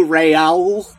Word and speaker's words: Rahul: Every time he was like, "Oh, hello, Raul." – Raul. Like, what? He said Rahul almost every time, which is how it's spelled, so that's fair, but I don't think that's how he pronounced it.